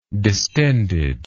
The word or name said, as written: distendedness